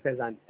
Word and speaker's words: Faizan: Faizan, sir. Faizan. – Faizan. Okay. – Faizan. F for France. – Faizan. How do you spell it? – F-A-I-Z-A-N.